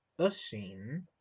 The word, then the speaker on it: Oisin: If it does not have the accent, which you call a fava, it's pronounced usheen. And if it does have the accent, it's pronounced osheen. So the S-I-N is sheen. Usheen or osheen.